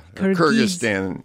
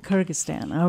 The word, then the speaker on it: Kyrgyzstan: And you're going to have to tell me how to pronounce that country. – Kyrgyzstan. – Kyrgyzstan, okay.